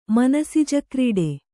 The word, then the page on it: ಮನಸಿಜಕ್ರೀಡೆ: Kannada